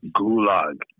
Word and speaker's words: Gulag: Okay, it's pronounced Gulag. Right? – Are you serious? – It's the damn Gulag.